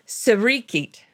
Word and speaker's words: Sirikit: This is the birthday of Sirikit, or something like that. It's spelled S-I-R-I, like Siri, and then K-I-T, who was a revered monarch.